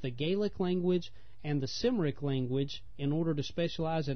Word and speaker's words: Cymric: Well, the second one is pronounced with a hard C, a K sound, Cymric.